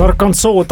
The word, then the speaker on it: Arkansas: Hästi pakutud, Arkansas taaskord õige vastus. Eksitus paljude jaoks tuleb tõenäoliselt sellest, et sarnase kirjapildiga Kansas hääldub ka lõpuosas tähtähelt samamoodi.